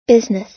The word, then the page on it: business: American English